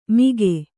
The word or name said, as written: ಮಿಗೆ